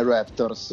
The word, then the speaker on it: Raptors: come l'hai detto bene Raptors eh Ora guarda.